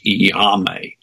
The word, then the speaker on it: iaomai: Okay, so this word, Iame, which is sort of spelled, it's spelled I-A-O-M-A-I, which is quite unusual pronunciation, Iame.